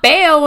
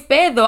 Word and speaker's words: pedo: I just learned this year that it's not peo when you fart, it's pedo. I'm like, bitch, pedo. Where that D came from?